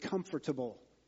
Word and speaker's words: comfortable: The word is not spelled C-O-M-F-T-E-R-B-L-E. It's spelled C-O-M-F-O-R-T-A-B-L-E. For the longest time, I pronounced it comfortable. That's how it's spelled. And Kathy's like, no, it's comfortable.